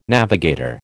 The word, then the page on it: navigator: American English